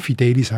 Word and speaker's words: Fidelis: Fidelis heisst treu, sicher, zuverlässig. Und auch das Wort oder der Name wird auf der zweiten Silbe betont.